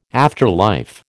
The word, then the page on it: afterlife: American English